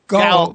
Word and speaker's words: Gowell: Gowell.